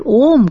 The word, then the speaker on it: Om: And when we say that Om consists of three syllables, A, Vu, Ma, this Omkara, Om, encompasses the three states of consciousness itself.